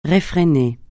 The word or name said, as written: réfréner